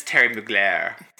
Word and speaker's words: Thierry Mugler: So they've been representing and producing shows for brands like Longchamp and Vivienne Westwood, Valentino, Jeremy Scott, Paco Rabanne, Thierry Mugler, Bulgari, Christie's, Agent Provocateur. Mm, and those are the only ones I can pronounce. And it's Terry Mugler.